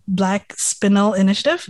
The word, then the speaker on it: Black Spinel Initiative: I'd love to learn a bit more about um, your um, initiative, the Black Spinel Initiative. Did I pronounce that right? – Yeah, perfect.